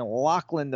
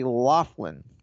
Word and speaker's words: Laughlin: I've been corrected many times from people in my inbox that I was saying Laughlin, Nevada, and it's actually Laughlin. But how the fuck would I know? I've never been there before. – So who cares? – It's spelled L-A-U-G-H-L-I-N. So why the fuck would it be Laughlin? But it is Laughlin, Nevada.